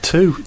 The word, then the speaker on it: two: Like two. Two is the only word, apart from... no, I think it is just two. 20. Two is the only word that has TW and you don't pronounce it tw.